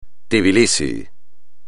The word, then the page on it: Tbilisi: espagnol